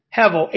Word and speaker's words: Hevel: I believe it's something along the lines of Hevel. Hevel, H-E-V-E-L, is how you would spell the pronunciation of that.